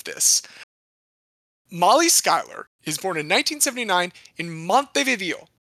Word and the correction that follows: Montevideo: Sorry, that's Montevideo, because it is not pronounced in Spanish, because it's in Minnesota.